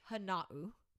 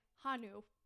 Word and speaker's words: Hana'u: Tell us how to pronounce it. – So it is pronounced Hana'u. – Hana'u? – Yep. Hana'u Photography. – Okay. I mean, it kind of looks like it's spelled like that. It does. You literally, yeah. Hana'u. – So in my head, I was saying Hanu. Hanu.